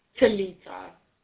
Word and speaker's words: Talitha: Talitha.